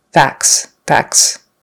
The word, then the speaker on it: facts: By the way, on facts, it's not highlighted simply because we usually don't pronounce that T. It becomes more like an F-A-K-S sound or F-A-C-S sound. Facts, facts.